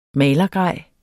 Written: malergrej

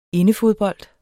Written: indefodbold

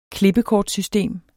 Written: klippekortsystem

